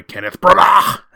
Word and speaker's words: Kenneth Branagh: directed by Kenneth Branagh, is how we say his name here.